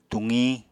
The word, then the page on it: tuqiñ: Apatani